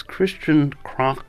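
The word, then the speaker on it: Christian Kracht: Today, my guest is Christian Krock. The best thing for you to know is that it's spelled K-R-A-C-H-T.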